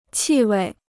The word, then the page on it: 气味: qì wèi